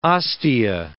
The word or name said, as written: ostia